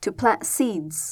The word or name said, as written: to plant